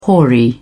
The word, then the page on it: hoary: British English